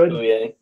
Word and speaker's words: Bouye: Bouye. I never can pronounce his last name. – I think it's Bouye. Yeah, that's the way I read it.